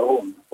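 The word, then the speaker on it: Rhône: Ja, det står Rune, är det Rende? R-H-O-N-E. – Ja, det är Ron.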